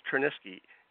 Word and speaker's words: Tryniski: All I know is that my name, my real name, is not pronounced Tryniski. It's pronounced, I can't pronounce it, but they changed it so people at least pronounce it in the United States.